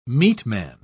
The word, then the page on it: meatman: British English